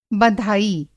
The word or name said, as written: बँधाई